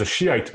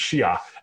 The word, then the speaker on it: Shia: So I was raised as a Muslim, as a Shiite Muslim. And the proper way to say it is Shia.